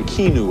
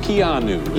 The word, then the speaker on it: Keanu: I was saying Keanu or something. It's Keanu. Is that correct? – I love movies.